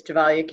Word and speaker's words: Gevalia: Um, first of all, I, I would, it's Gevalia Cafe, but that's okay. Um, everybody mispronounces it, and um, even internal people at, at Kraft used to.